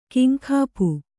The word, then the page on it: ಕಿಂಕಾಪು: Kannada